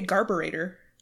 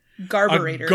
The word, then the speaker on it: garburator: Like, you guys call it a garbage disposal, but Canadians say garburator. – What? – Garburator. – Like carburetor, but with gar? – With a G. Garburator.